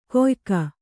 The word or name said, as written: ಕೊಯಕ